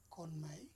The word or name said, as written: Konmei